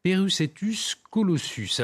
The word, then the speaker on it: Perucetus colossus: on va à présent parler, j'espère que je vais bien le prononcer, du Perucetus colossus. Alors dites-nous, euh, qu'est-ce que ça signifie C'est une baleine colossale du Pérou, c'est bien cela son nom Exactement, et vous avez très bien prononcé euh, son nom.